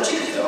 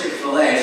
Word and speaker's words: Chick-fil-A: She said, no, Chick-fil. I said, no, Alice is pronounced Chick-fil-A.